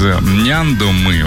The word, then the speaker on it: Няндомы: От всей души поздравляю свою любимую мамочку Исакову Людмилу из Няндомы. Ударение на первый слог.